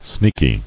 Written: Sneaky

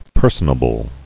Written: Personable

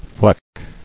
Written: Flecking

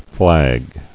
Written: Flagged

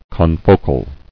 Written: Confocal

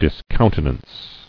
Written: Discountenancing

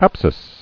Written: Apsides

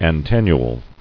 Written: Antennule

